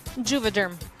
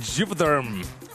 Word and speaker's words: Juvederm: Juvederm. – Juvederm.